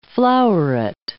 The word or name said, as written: flowerette